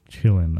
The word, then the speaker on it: qilin: It's spelled Q-I-L-I-N. But they pronounce it as Chillin, I think.